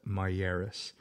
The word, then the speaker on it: Miyares: As we spoke to Myers, and it's spelled M-I-Y-A-R-E-S, Myers, he stated that he seen fireworks in the area of Parker while he was running, jogging.